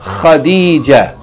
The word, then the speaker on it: Khadija: Like the name of the first wife of the Prophet sallallahu alaihi wasallam is kha, kha, Khadija, Khadija, and